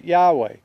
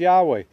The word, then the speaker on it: Yahweh: I pronounce it Yahweh. I believe it's Yahweh.